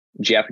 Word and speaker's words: Geoff: Jeff Gannon, but it's spelled G-E-O-F-F, and then Gannon, G-A-N-N-O-N.